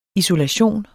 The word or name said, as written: isolation